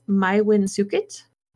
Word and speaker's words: Maewyn Succat: Now, his true name, and let me spell it for you, was actually M-A-E-W-Y-N-S-U-C-C-A-T. And when I looked up how to pronounce it, so please don't be mad at me, I'm trying here, it's Maiwin Sukit.